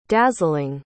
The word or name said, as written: dazzling